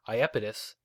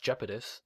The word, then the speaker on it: Iapetus: Iapetus, sometimes pronounced Jeopetus, is the third largest moon of Saturn and 11th largest moon in the solar system.